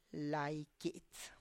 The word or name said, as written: lai kits